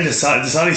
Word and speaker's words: Dasani: And responded back by asking her, do you know what Dasani water is? Is that the right pronunciation? Dasani. – I think Dasani sounds better. – It sounds a little bit different.